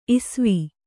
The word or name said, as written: ಇಸ್ವಿ